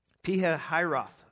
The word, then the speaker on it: Pi-hahiroth: that they turn and encamp before Pi-hahiroth." I looked that up, and that's how you say it.